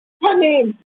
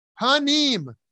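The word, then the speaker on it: Panim (פנים): Panim. – Panim. Panim literally means face, but also means interiority, the inner stuff of the self. When the prefix bet or be, resulting in turning panim into bifnim,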